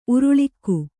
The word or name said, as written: ಉರುಳುಕ